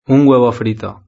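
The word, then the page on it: un huevo frito: Spain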